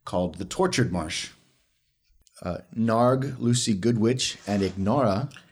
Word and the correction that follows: Inara: Inara, the G is silent.